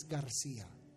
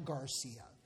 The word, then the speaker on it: Garcia: The correct way to say it is Garcia, not Garcia.